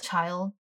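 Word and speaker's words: Childe: Child? – Child.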